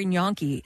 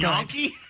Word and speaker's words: gnocchi: They have cauliflower gnocchi, and it's so good. – Gnocchi? – Yeah. – What? – Like a donkey? – That's how you say it.